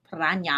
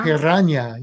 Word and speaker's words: piranha: Piranha. – You know that's the proper way to say it. – Piranha. – Piranha. You gotta roll the R. – Piranha. Piranha. – And then the it's an nya.